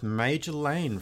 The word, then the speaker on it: Majorlaan: It's time for our interview with Major Lane from Vancouver. Now, the band name is spelled M-A-J-O-R-L-A-A-N, but pronounced Major Lane.